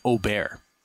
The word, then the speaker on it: Åberg: Excellent pronunciation on Åberg.